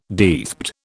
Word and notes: deathbed: British English

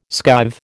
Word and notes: skive: American English

